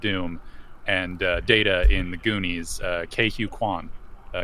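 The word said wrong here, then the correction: Ke Huy Quan: Ke Huy Quan.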